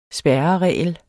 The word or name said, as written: spærreregel